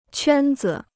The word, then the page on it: 圈子: quān zi